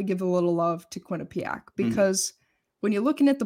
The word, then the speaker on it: Quinnipiac: All right, team to watch is Quinnipiac. Did I say it correctly? – Always, yeah, the hard one to say, but yes! – You got it.